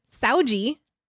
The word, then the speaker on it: Saúde: Or should I say Saúde? That's how you say it in Portugal, which is what we're tasting from tonight.